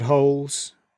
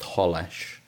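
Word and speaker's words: Holeš: Czech Republic had a player called Holes. – I did notice that when I was looking down the squad list. I believe, sadly, that the little accent on the S means that it's pronounced Holesh.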